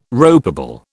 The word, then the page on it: ropeable: British English